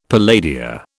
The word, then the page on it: palladia: British English